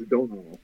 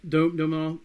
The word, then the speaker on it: Domhnall: Well, for me, I was reading somewhere else that, I'm going to say his name wrong. We sang Domhnall. – I think it's Domhnall. – Domhnall.